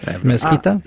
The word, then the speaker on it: Mezquita: The Mezquita Cathedral. Is that how I say that? – Mezquita. Mezquita.